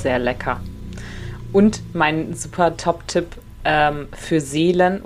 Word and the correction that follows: Selen: oder wie, wie wird das ausgesprochen? Selen. Selen. Selen.